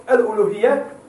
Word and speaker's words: Uluhiyyah: Uluhiyyah, das Wort Al-Uluhiyyah. Al-U-Lu-Hiyyah. Ja? Okay, eigentlich schreibt man Uluhiyyah so, ja? Stimmt's? Mit U. Uluhiyyah.